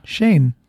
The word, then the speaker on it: Shein: But speaking of China, I saw an interesting article about that website, Shein? – Yeah, Shein. – Is that how you pronounce it? – I think so. – It's not Shine, right? – If anything, it would be like Shane. – Shane? – Yeah.